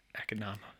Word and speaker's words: Economos: Yeah, Economos, not Economos. – I'm going to pronounce it in the Greek way and say Economos.